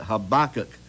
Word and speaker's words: Habakkuk: Verse 1 of chapter 3, a prayer of Habakkuk. That's how you pronounce it, Habakkuk, the prophet from Shigenoth.